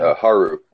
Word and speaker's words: Haru: How do you say her name? – Haru.